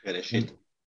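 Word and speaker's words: Bereshit (בראשית): Und dieses Wort im Anfang auf Althebräisch, Besherit oder wie das heißt, ich kann es leider nicht genau aussprechen. Bereshit. Genau. Ah, wie nochmal? – Bereshit. – Bereshit, genau, Bereshit. Dieses Wort hat auch eine piktografische Bedeutung,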